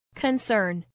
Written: concern

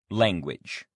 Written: language

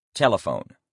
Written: telephone

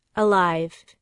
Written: alive

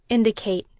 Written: indicate

